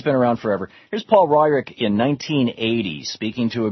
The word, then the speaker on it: Weyrich: Weyrich.